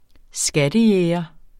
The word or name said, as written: skattejæger